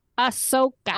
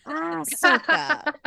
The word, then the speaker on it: Ahsoka: Ahsoka. – Oh boy. – Ahsoka. Abby too writes in Ahsoka's background. If anyone is interested in learning more about Ahsoka's background, Disney Plus has a great Ahsoka Tano Essentials playlist.